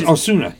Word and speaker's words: Osuna: Oh, Usana. You know? – Hmm? – Yeah, what's his name, Usana? Yeah, they traded Giles to Toronto for that Usana. – Yeah, because... – Osuna. – Yeah, rhymes with tuna.